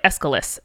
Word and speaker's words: Aeschylus: This is by Aeschylus, a play called The Libation Bearers.